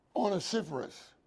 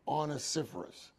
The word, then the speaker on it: Onesiphorus: I don't know how you pronounced it, Joshua, but the way to pronounce it is onesiphorus. Onesiphorus, accent on sif, short O, onesiphorus.